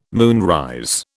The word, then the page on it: moonrise: British English